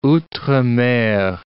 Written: outre-mer

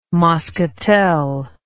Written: moschatel

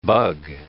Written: bug

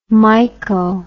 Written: myco

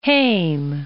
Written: Heem

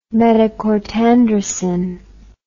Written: metacortandracin

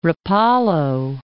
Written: Rapallo

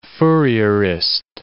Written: Fourierist

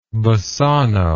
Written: Bassano

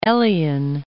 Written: Elion

